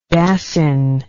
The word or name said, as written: Dassin